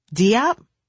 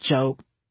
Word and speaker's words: Diop: Diop? Oh, it's pronounced Jope, as in rhymes with hope, I would say with a smile. But it's spelled D-I-O-P, Diop, they would say with confused indignation. Yeah, I know, it's still Jope.